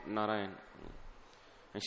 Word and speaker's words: Narayana: And later he was saved simply because he chanted the name of Narayan. And Srila Vishwanath Chakravarti Thakur explained that in Narayan there are four syllables. Narayana.